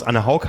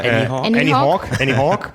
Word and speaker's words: Anne Haug: Ich weiß inzwischen auch alle Aussprachen von Anne Haug, die bei uns Anne Haug heißt. – Anne Haug? – Im Englischen, das kennen wir auch schon länger. – Annie Haug. – Im Französischen Anorg. Anorg.